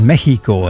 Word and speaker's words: Mexico: Mexico, maar eigenlijk zeggen ze daar Mexico, hè, als je het goed wilt uitspreken.